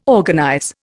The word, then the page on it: organize: Indian